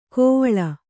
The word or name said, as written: ಕೋವಳ